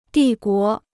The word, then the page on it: 帝国: dì guó